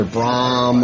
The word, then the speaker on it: Bram: It's either Bram or or they assume it's Brian or they think something's wrong.